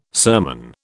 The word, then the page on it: sermon: British English